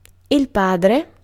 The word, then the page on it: père: [lø pɛʁ]; /pɛʁ/